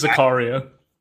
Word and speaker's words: Zakaria: I know that a lot of people might be like, actually, Zachariah, I don't know how to say it. – It's Zakaria.